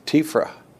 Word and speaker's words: TEFRA: And Eddie, who was a great punster, sort of said, you've always pronounced that wrong. It's TEFRA. So you can have TEFRA too.